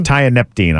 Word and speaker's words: tianeptine: are adding, a, uh, it's called a tianeptine. Ty- tianeptine. I say think. it again. tianeptine. – I it's, believe is how you say that.